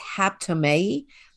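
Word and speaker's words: haptomai (ἅπτομαι): In the Greek, it's pronounced haptomai.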